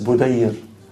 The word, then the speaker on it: Budayr: My name is Rida Bidayr. The word Bidayr at the end, it should be pronounced as Budayr, which means the moon in the smallest stage, which is called the crescent.